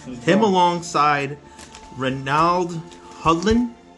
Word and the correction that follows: Reginald Hudlin: I'm saying his name correct. – Reginald? I don't even know. – They're longtime... – Oh, Reginald Hudlin. – Reginald, there you go. Oops, I said it wrong.